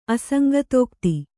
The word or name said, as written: ಅಸಂಗತೋಕ್ತಿ